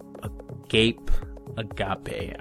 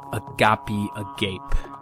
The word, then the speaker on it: Agape Agape: I am reading this fortnight, just finished, William Gaddis's Agape Agape. I believe after a little research that, actually I said it wrong, Agape Agape. I believe that's the correct pronunciation.